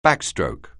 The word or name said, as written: backstroke